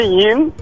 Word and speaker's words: yin: Birincisi isimleri doğru telaffuz etmekte fayda var. Birincisi yin, ikincisi yang. Yani yinin sonunda g yok.